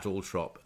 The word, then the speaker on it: Althorp: I think the Spectator played Lord Spencer's 11 at Althorp, as it's correctly pronounced, I think, in about 2005 or something.